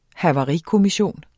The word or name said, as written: havarikommission